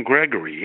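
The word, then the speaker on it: Gregorie: And if I remember right, it was originally conceived by Edsel Ford, but of course the designer, Eugene, I think it was Grigiori? Am I saying that right? – It was Eugene Gregory, yes.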